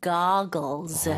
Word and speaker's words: goggles: 네, 발음 주의하셔야 돼요. 고글보다는 영어로는 goggles. 그리고 두 개, 그 R이 두개 있으니까 복수 형태로 거의 쓰죠. goggles.